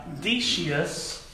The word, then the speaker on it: Decius: He was the bishop of Carthage, and during his tenure as bishop, persecution under the Roman emperor Decius, spelled like this, D-E-C-I-U-S, Decius, persecution began heating up when Decius took the throne.